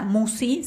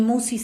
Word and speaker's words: Musci: El canal lleva mi nombre, Marisa Musi, se escribe todo junto como si fuera una sola palabra, y Musi se escribe M-U-S-C-I.